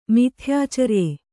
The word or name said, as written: ಮಿಥ್ಯಾಚರ್ಯೆ